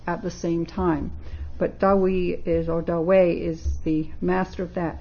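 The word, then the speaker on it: Dahui: And her present focus of study is translation of the letters of Zen master Da Hui, pronounced actually now Da Wei.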